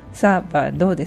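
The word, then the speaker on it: sábado: 同じように土曜日という意味のサバド、サバドで 3 音節でサのところの A の上にアクセントです。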